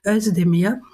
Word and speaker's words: Özdemir: Isabel Kadimatori, ich stolper immer noch, weil der Name lange Zeit falsch ausgesprochen wurde, übrigens so wie der von Cem Özdemir, aber jetzt sagen ja alle ganz wunderbar Özdemir.